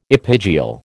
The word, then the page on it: epigeal: American English